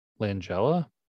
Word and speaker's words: Langella: Langella.